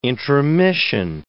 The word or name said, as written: intromission